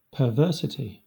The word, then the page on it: perversity: /pə(ɹ)ˈvɜː(ɹ)sɪti/